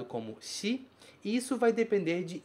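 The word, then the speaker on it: yon (四): O que eu quero que você saiba é: este caractere significa 4 e pode ser lido como yon ou pode ser lido como shi.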